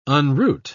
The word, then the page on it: unroot: British English